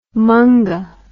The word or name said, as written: munga